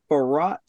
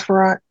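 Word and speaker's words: Faurot: It's Memorial Stadium at Farrah Field.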